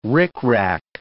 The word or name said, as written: ricrac